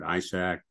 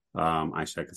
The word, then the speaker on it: Ishak: Again, my name's Alfred Ishak. Ishak is spelled I-S-H-A-K.